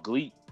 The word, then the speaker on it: Gleat: But I've been watching that, and this company called Gleat. I think it's pronounced Gleat, but it's spelled Gleat, G-L-E-A-T, and that's like a shoot fighting kind of company in Japan.